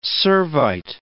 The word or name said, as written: Servite